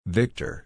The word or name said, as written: victor